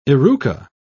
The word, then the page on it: eruca: British English